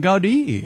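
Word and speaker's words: Gaudí: Gaudi. – Yeah, that's how they pronounce it.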